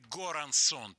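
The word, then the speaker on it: Göransson: Людвиг Горансон, так его фамилия в американском произношении, на самом деле по-шведски это, наверное, Йорансон читается.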